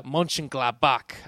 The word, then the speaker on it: Mönchengladbach: I have a 0-3 loss to Borussia Mönchengladbach. I believe that's how you say it. – That's pretty good. – That's pretty close, good, yeah.